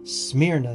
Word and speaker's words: Smyrna: Smyrna is how you say the name.